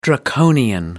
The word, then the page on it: draconian: American English